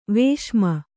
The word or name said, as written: ವಿಷಮೆ